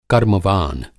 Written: कर्म-वान

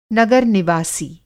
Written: नगर-निवासी

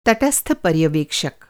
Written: पर्यवेक्षक

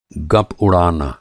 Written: गप

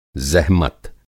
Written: ज़ेहमत

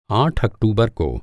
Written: आठ

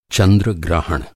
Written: ग्रहण

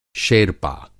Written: शेरपा

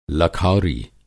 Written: लखौरी

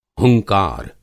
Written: हुंकार

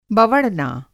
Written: बवँड़ना

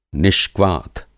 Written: निष्क्वाथ

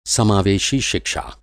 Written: समावेशी

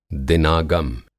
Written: दिनागम

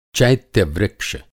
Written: चैत्यवृक्ष

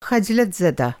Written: ख़जलतज़दः

